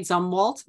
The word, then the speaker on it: Zumwalt: But you also introduce us to a really interesting person who I never knew anything about, Admiral Elmo Zumwalt. Am I saying that right? – Zumwalt.